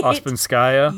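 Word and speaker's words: Ouspenskaya: Ospenskaya.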